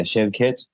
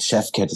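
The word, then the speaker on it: Şevket: Und habe dann einfach die Falschaussprache meines Namens. Ich heiße ja Chevcat. – Ja. – Und Chefcat, das war dann so naheliegend, klang auch irgendwie cool.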